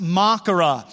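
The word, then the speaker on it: makara (μάχαιρα): This sword of the Spirit in the Greek language is pronounced makara, which is a large knife for cutting up flesh.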